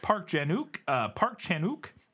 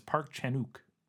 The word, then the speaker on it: Park Chan-wook: Park Chan-wook, and you don't pronounce the W from everything I was able to find. Every time I heard somebody say it, it was Park Chan-wook, like one word, but who knows.